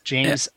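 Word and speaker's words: Ijames: James.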